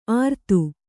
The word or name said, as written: ಅರ್ತು